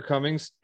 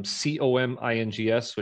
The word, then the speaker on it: Comings: Yeah, uh, my name's Andrew Comings, and uh, I, my last name is spelled um, C O M I N G S, which is different than some other people spell it. But just think of think of the opposite of goings.